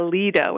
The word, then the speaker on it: Lido: I have read a book called I think it's pronounced the Lido. It's L-I-D-O.